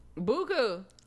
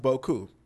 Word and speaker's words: beaucoup: Now, a lot of y'all niggas, including myself, pronounce it buku like savage shit. But it's actually pronounced boku. Boku is an adjective, and it's spelled B-E-A-U-C-O-U-P. Boku.